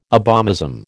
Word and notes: abomasum: American English

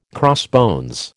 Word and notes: crossbones: American English

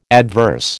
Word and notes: adverse: American English

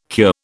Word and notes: cure: British English

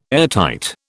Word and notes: airtight: British English

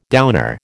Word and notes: downer: American English

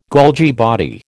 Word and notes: Golgi body: American English

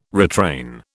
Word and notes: retrain: British English

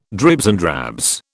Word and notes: dribs and drabs: British English

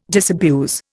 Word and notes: disabuse: Indian

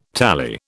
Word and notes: tally: British English

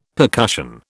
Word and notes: percussion: British English

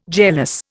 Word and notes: jealous: Indian

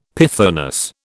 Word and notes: pythoness: British English

